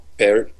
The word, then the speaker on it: Pärt: Do you know the right pronunciation of his last Pärt.